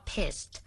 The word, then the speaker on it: pissed: การออกเสียงจะต้องมีเสียงที่ท้ายคำนะคะเป็นคำว่า pissed เพราะคำว่า piss P-I-S-S